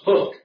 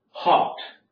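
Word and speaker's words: houghed: I thought it was hoofed all the chariot horses, but if you listen to it, it's an old Scottish term and it's pronounced hocked.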